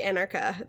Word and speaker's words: Anarcha: So the three women that were named in his records are Anarcha. Yeah, because I wrote down it's like anarchy, so I could remember how to pronounce it correctly. Anarchy, Anarcha.